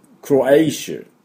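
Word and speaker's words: Croatia: クロアティのところね、クロエイシュ。クロエイシュって言うんですよ。ね。エイシュなんです。はい。ですから、クロアチアじゃないですね。クロアイシ、シ、シって音になりますね。これが、要注意です。クロエイシュ、クロエイシュです。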